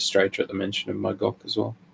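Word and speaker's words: Mog'ok: Mogok? With a hard G. – M O G apostrophe O K. Mogok, right.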